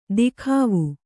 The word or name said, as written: ದಿಖಾವು